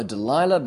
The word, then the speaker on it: Delilah: Afterwards, he finds a young Philistine lady in Nachal Sorek, whose name is Delilah. In English, we all know Delilah, but her name is Delilah. That's how it's pronounced in Hebrew.